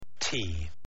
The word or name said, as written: tea